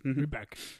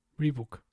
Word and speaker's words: Reebok: De, hecho, sí, mira, en, pone la pronunciación Reback. Reback. – Es, es Reback, exacto. Reback. – Pues mira, qué cosas hay que aprenderse con estas edades, ¿no? Como llevas toda la vida diciendo mal Rebook, Reback.